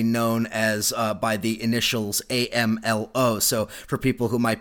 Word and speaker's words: AMLO: AMLO, that's how it's pronounced here.